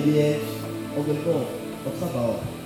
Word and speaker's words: Sabaoth: So that because God, the Lord of Sabaoth, I'm not an Hebrew, so I cannot really pronounce it, but his name is just S-A-B-A-O-T-H, Sabaoth.